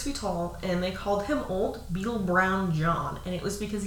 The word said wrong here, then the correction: Browed: Old Beetle Browed John. – Browed, not brown.